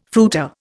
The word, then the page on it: fruiter: Indian